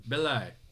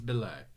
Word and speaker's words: Belli: Is it Ely? Eli? – Eli? B-E-L-L-I. – Belly. It's Belly. It's Melvin Belly, right? – We'll go with that.